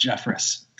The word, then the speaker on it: Jeffress: Jacob Barnes, Matt Albers, Jeremy Jeffries, Josh Hader. – I love that you can't even... You mispronounced Jeffress. It's fantastic. – It should be Jeffries, so I don't consider that an error.